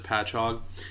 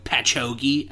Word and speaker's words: Patchogue: I think you pronounce it Patchogue, but there are certain people who I'm close to that call it Patchogie, and I don't know where they get that from.